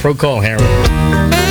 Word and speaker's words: Procol Harum: Is it Procol or Procol? Procol Harum. Procol Harum. The name of the band.